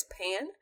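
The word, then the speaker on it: Paan: They are calling them, and I'm assuming you pronounce it as Pan, because it's P-A-A-N.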